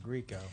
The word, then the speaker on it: Grieco: How do you spell it? – G-R-I-E-C-O, Greco.